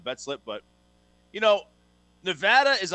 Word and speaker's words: Nevada: But my team on upset alert is Nevada, or Nevada if you prefer to pronounce it that way.